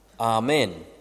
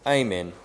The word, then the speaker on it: Amen: And from this ocean, another god arises called Amen, or Amen, depending if you're American or English.